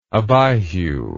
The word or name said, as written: Abihu